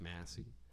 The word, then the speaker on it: MVSSIE: Her artist name is MVSSIE. – Okay. – Pronounced Massey.